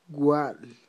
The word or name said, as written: wa'l